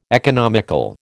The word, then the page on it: economical: American English